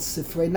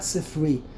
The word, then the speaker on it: Sifrei: That's why it's called Sifrei, not Sifri. Many people, unfortunately, they mispronounce, they read it as Sifri, in the Sifri.